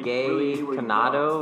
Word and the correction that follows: Condado: How do you pronounce the place? Condado. Condado.